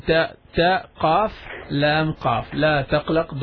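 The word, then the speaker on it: تقلق: It's not la taglaq, it's la taqlaq. – La taqlaq. That's it.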